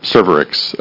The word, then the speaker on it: Cervarix: Is it how it's pronounced? – Cervarix.